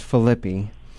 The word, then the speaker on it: Filippi: It's Filippi.